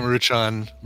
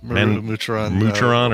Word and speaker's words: Maruchan: So it's even that brand, that Manchurian. – Yeah, Maruchan. – Maruchan, or how you say it? – Yeah.